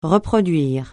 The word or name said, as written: reproduire